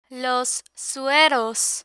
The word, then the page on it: los sueros: spanish